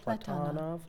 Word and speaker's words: Platonov: Am I saying the name right? Platonov? – Platonov? Yeah, you're saying it right.